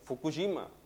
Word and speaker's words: Fukushima: de Fukushima.